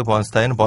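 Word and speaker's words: Bernstein: 어그 근데 둘이 영어 스펠링은 똑같은데 발음은 좀 다르더라고요. – 음. – 엘머는 번스틴이라고 읽어달라고 하고 그다음에 이제 레너드 번스타인은 번스타인이 음. 읽어달라고 하는데 둘 다그 어, 유태계 성인인데 유태계인데 어, 엘머의 경우는 이제 헝가리에서 아버지가 네.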